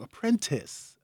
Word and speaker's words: Apprentice: Why do I say that wrong? Apprentice. I'd be like apprentice.